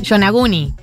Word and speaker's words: Yonaguni: Bad Bunny sacó un tema, que voy a buscar el nombre porque no lo sé pronunciar bien. Yonaguni. Yonaguni se escribe Y-O-N-A-G-U-N-I. – Es una comida, ¿no?